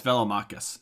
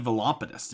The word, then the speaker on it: Velomachus: Yeah, it's Velomachus. You said Velompidus, didn't you? – I said Velomachus.